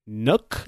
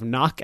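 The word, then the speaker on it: Nøkk: But for this week, we will dive into the new operators who are Nook is how they pronounced it. – Mm-hmm. – You're probably going to hear a lot of Nock and a lot of Nook, so I would just get used to it.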